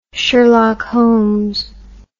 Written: sherlock holmes

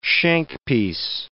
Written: shankpiece

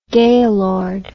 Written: gayload

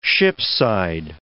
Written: shipside

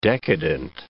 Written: decadent

